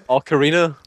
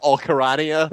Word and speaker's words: ocarina: Ocarina. – Ocarina, that's the one. – Not Ocarania.